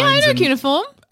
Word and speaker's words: cuneiform: Cuneiform? – Cuneiform.